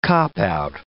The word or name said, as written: cop-out